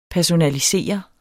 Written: personalisere

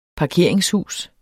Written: parkeringshus

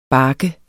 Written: barket

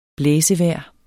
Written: blæsevejr

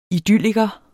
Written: idylliker

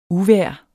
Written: uvejr

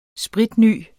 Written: spritny

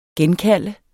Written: genkalde

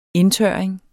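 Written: INDTØRRING

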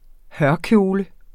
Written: hørkjole

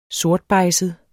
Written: sortbejdset

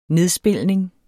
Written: nedspilning

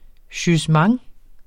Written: jugement

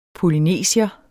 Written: POLYNESIER

